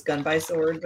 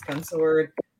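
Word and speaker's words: Gun X Sword: I always thought it was Gun by Sword, but it's Gunsword.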